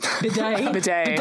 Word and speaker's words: bidet: Called B-Days. – B-Days. – Is that how you say it? – I don't know. – Bidet.